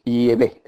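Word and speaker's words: Yahweh: y es se sabe que es yeve, esa es la pronunciación.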